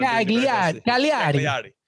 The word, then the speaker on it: Cagliari: I don't know if I'm saying that right, but Cagliari.